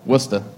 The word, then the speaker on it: Worcester: How do you say Worcester? – Worcester. – Worcester. How do you get that from W-O-R-S-C-E-S-T-R? How? – R's are overrated.